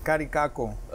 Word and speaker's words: Caricaco: Caricaco.